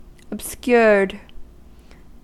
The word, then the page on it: obscured: American English